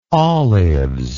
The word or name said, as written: olives